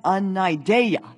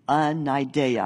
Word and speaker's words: anaideia: Anaideia. That's how you say it in the Greek. Anaideia.